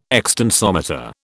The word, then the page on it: extensometer: British English